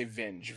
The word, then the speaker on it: Vinge: It's called Rainbow's End by Werner Vinge. – Yeah, it's pronounced. If I was going to read it, I'd say Vinge, V-I-N-G-E, Werner Vinge.